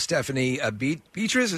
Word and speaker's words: Stephanie Beatriz: Now, you had one of your co-stars directed an episode herself, Stephanie Beatriz. Is that how it's pronounced? – Yeah.